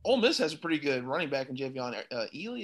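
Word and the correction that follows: Jerrion Ealy: Is that how you pronounce his name? I know. – Yeah, Jerrion Ely. Yeah. – Jerrion Ely.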